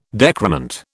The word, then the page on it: decrement: British English